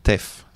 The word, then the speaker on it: TAFF: Y cierra el medley, T-A-F-F, TEF, se escribe. T-A-F-F.